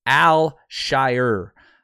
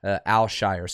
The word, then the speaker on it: Alshire: Now, before we jump into his college and kind of what he was able to accomplish there, his name is pronounced Azeez, right, U-H, if you look at the pronunciation, Azeez, and it's Alshire. Alshire. So like if you think about like the Hobbits, right? The Shire, Alshire. So Azeez Alshire is the way you pronounce it, which pronouncing things is not my thing.